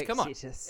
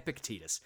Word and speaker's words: Epictetus: I can't pronounce Greek names. – Epictetus. – Come on. Epictetus. "A